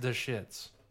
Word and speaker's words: Deschutes: I think it's pronounced Deschutes.